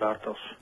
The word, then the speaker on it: Bartosz: Mein Name ist Bartosz. – Bartosz. – Genau wie im Deutschen Janosz mit SCH.